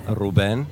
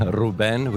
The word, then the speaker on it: Ruben: Actually, we pronounce it in Armenian, Ruben. – Ruben? Cool. – Ruben, with a kind of emphasis on E.